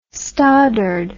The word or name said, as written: stoddard